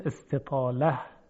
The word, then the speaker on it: استطاله: و اما صفتی که باقی مانده از صفات غیر متضاد، صفت استطاله هست که اصل اون تلفظ صحیح اون استطاله. خب ماده طول یعنی تا واو و لام رو اگر به باب استفعال ببریم، استفعل یستفعل و استفعال، استطول که می شود استطال یستطیل و استطاله، و مستطیل هم از همین به اصطلاح باب هست.